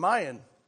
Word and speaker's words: Mayan: If I'm not, I'm Mayan. I'm not... You know. It rhymes with lion, so anyhow, that's, bam, that was great.